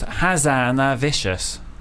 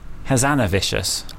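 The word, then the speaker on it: Hazanavicius: It actually is pronounced Hazanavicious. Hazanavicious.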